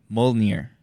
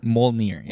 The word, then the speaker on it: Mjolnir: Mjolnir, is it? Dude, am I saying it wrong or what? – Okay, hold on, help me out here. – Mjolnir. – Mjolnir. – Mjolnir. Yeah. – Okay, there we go.